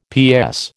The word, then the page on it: PS: American English